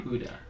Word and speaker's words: Huda: I never know how to say her name. – Huda. – Huda. Like Huda? – Huda!